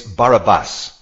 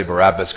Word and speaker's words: Barabbas: It's Barabbas, accent on the third syllable. I may say Barabbas because I'm using the English convention.